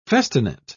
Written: festinate

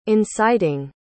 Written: inciting

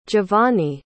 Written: giovanny

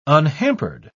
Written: unhampered

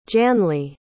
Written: Janli

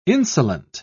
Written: insolent

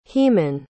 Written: heman

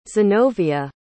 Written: zenovia

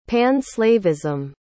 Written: pan-slavism

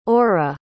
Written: orah

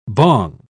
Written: bong

